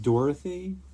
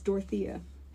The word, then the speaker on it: Dorothea: Isn't it Dorothy? – No, it's Dorothea. – How's it Dorothea? – Because it's spelled D-O-R-O-T-H-E-A.